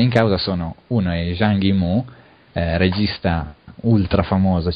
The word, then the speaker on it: Zhang Yimou: Passiamo al successivo, quindi Sangue Facile di Zhang Yimou, come si pronuncia? – Sì, va bene.